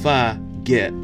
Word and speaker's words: fugit: And what I mean by that clever play on words, which is F-U-G-I-T, so pronounce fuh-git, as in like forget it, but you know, if y'all dirty mind say and think about something else, that's on you.